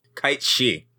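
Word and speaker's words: Cait Sith: Is it pronounced Katseth or Katseth? – Kite-she. – Kite-she? What the fuck? – It's Gaelic.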